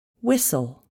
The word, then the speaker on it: whistle: The letter T is not pronounced in these common words. Castle, Christmas, listen, whistle.